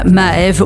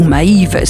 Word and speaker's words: Maeve: quel titre absolument magnifique aux accents rave old school signé Jozef, ça s'appelle Nocturne et c'est sorti sur le label Maeve ou Maeve si vous le prononcez plutôt à l'anglaise.